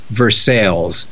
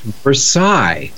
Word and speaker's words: Versailles: You see this word and you hear somebody reading it out loud and you go, oh, it's not pronounced Versailles. It's pronounced Versailles.